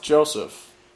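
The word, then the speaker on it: Joseph Fiennes: But his brother's name is still pronounced Joseph. – Not Jose. Yeah. – It's Joseph.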